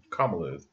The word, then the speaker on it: Kamala: Kamala, yeah.